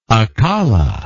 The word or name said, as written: akala